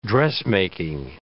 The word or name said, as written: dressmaking